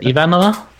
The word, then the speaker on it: Ivanova: Is it Ivanova? – Susan Ivanova is how they say it.